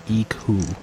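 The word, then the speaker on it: Ico: On Wikipedia, they have an IPA guide for how to pronounce Eco, and it is just the letter I, which apparently in IPA is like Eid or Pete. So, Eco. Eco.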